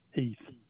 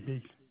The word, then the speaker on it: Heath: Thomas Heath. Maybe Tommy Heath.